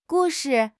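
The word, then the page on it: gu4 shi5: tone 4; tone 5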